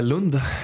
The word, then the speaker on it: Lund: Aus, wie spricht man das aus? Lund oder Lund? Äh, Lund, ja.